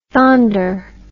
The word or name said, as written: thonder